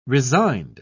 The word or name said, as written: resigned